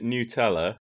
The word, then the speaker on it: Nutella: They should if you go anywhere else in the world, they pronounce it Nutella. And it's only the UK that gets it wrong, because we figure it's got nuts in it, so it's called Nutella.